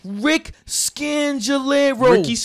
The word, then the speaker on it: Rich Scangarello: Rick Scangarello. Rich Scangarello.